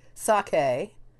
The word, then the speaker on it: sake: Well, no, the Japanese, you're pronouncing the E, sake.